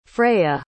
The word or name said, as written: freya